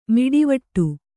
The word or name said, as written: ಮೀಟುವಡೆ